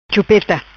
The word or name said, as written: Chupeta